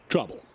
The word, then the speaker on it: trouble: Don't want to get in treble. – Trouble. – What? – Oh, I think you mispronounced trouble.